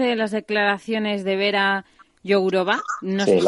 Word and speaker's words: Jourová: No tengo claro cómo se pronuncia, pero Vera Jourová, tú has pronunciado muy bien.